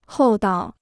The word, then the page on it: 厚道: hòu dao